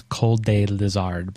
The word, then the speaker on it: Cold Lizard: So it's Cold Day Lizard.